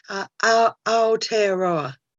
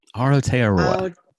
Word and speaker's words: Aotearoa: Welcome, and help me pronounce. How do the Maori and how do you say New Zealand these days? – Kia ora, and kia ora, everybody from New Zealand. Aotearoa. – Aotearoa.